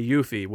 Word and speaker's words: Yuffie: We say Yuffie, which is her name.